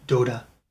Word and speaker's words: Dota: I know your game is Dota, if I'm pronouncing that in the right way. – Yes, that's the right way. It's called Defense of the Ancient, or in short, Dota.